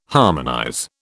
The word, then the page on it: harmonize: British English